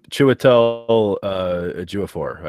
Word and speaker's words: Chiwetel Ejiofor: Chiwetel Ejiofor, I believe, is how they pronounce his name.